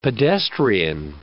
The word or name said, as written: pedestrian